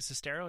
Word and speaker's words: Sestero: But when Greg Sestero, is that how you say his name? – Yes.